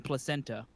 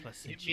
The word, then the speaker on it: Placentia: We didn't name a thing Placenta. – Fair. – Placentia. It means pleasant place to live.